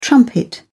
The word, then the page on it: trumpet: British English